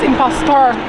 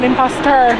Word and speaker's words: imposter: Is it, why is it pronounced impostor and not imposter? – It, it's imposter. You're pronouncing it wrong.